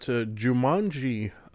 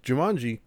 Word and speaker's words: Jumanji: We've got Jumanji, The Next Level first. Oh, sorry, I mispronounced that. Jumanji, The Next Level.